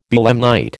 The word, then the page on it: belemnite: American English